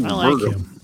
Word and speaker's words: Burgum: Doug Burgum? Is that how you pronounce his name? – Yeah, Burgum. – I like him. Doug Burgum.